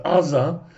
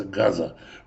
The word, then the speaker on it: Газа: Потому что буковка Айн, она в некоторых диалектах читается не как А, а как Г. Поэтому есть то, что на иврите город называется Аза, на русском языке и в английском языке называется Газа.